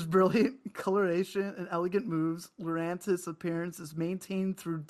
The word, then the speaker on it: Lurantis: And it's Lurantis.